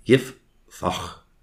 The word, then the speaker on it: Jephthah: Jephthah, pronounced Yiphach in Hebrew,